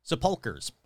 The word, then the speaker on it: sepulchres: How do you fucking pronounce sepulchres? All right, sepulchres.